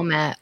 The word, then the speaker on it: OMAT: Oh, do you pronounce it OMAT? – Mm-hmm. Yeah. Oh, OMAT.